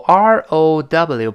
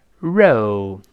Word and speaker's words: row: 然后后面出现重要搭配是 in a row，后面这个 row r o w 本来可以表示一排一列这个意思啊，注意读的时候一定勾舌头，不能读成肉肉，那是那个肉包子那个肉了，舌尖要勾回来，r r，跟老师读一下 row row，好 in a 自然读成 in a in a 连在一块儿，再读这个 row，我们大声来遍。